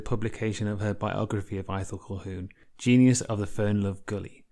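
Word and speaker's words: Ithell Colquhoun: So we're talking about Aethel Colquhoun today.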